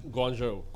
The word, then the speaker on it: Guangzhou: Did I say that right? – Guangzhou. – Guangzhou.